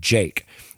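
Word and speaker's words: JVKE: The video begins with this artist, Jake. And it's J-V-K-E, but it's pronounced Jake.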